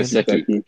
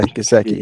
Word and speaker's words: Gesicki: Is that his, how do you say his last name? – Gesicki. – Yeah, Gesicki. Yeah.